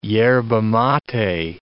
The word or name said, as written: yerba mate